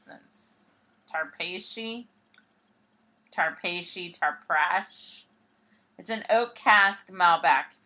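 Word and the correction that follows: Trapiche: So, let me tell you, it's Trapeche. – Okay. – Is its pronunciation.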